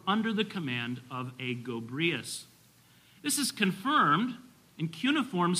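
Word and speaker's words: Gobryas: The Greeks tell us this general's name was Gobrius, or Gobruus is how you would pronounce it.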